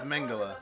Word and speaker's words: Mengele: That's a questionable pronunciation. – Yeah, I thought it was Mengele. – Yeah. – It is. – You got it right.